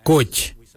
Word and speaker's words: goodj: Karli is spelled G-I-I-L-Y-I, karli. For the traditional stone axe, we say koch, koch. Koch is spelled G-O-O-D-J, koch.